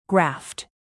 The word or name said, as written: graft